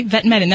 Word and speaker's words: Vetmedin: He's in called Vetamin, I think it's pronounced. Vetamedin. He is on that. – Okay, Vetmedin.